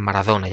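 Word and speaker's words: Maradona: Ε, το Μαραντόνα είναι Μαραδόνα για παράδειγμα.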